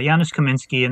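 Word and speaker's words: Janusz Kamiński: Is it Janusz Kaminski? Am I saying it right? – Yeah, Janusz Kaminski.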